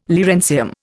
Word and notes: lawrencium: Indian

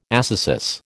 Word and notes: ascesis: American English

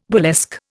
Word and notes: burlesque: Indian

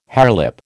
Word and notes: harelip: American English

